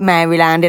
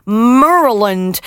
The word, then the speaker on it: Maryland: It's in Maryland, but they don't pronounce it Maryland in America. They pronounce it Maryland.